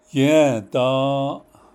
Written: yę́ędą́ą́’

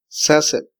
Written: saseb

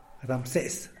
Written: ramsees

